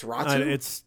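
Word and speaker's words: Räty: The Canucks got Anthony Beauvillier, Atu Rati. I might be saying that wrong. I don't give a shit. – Atu Ratu. – But he's got, his name is R-A-T-Y. It's Ratu.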